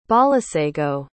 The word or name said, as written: balesego